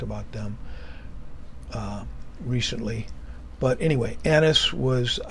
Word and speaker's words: Annas: Annas, and I mispronounced his name a couple times yesterday. I did finally get it right, but I said Ananias, which I think there's a couple of Ananiases.